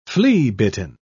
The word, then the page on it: flea-bitten: British English